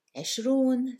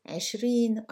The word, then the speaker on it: عشرون: Just a little note here that عشرون, ثلاثون, أربعون are usually pronounced in colloquials as عشرين, أربعين, and so on. When half the time we are grammatically wrong, this is how we pronounce them.